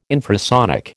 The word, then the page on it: infrasonic: American English